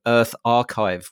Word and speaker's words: EarthArXiv: And that is your co-founder of something called Earth Archive, which is spelled ARXIV, which is a non-profit preprint server for the earth sciences.